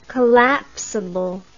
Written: collapsable

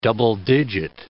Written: double-digit

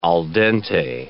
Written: al dente

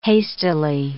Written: hastily

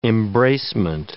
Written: embracement

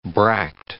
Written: bract

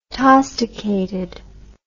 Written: tosticated

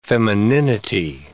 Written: femininity

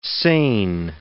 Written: seine

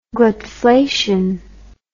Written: glutflation

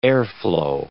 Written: airflow

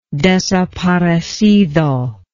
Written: desaparecido

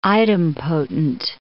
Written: idempotent

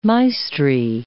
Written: maestri

